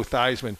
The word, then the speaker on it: Theismann: He changed his name to rhyme with Heisman. – Yeah, well, don't worry. I got a steady diet of that. But the one thing about Joe Theismann, I'll tell you, when he stepped inside the gridiron, you wanted nobody other than him to have the ball in his hands.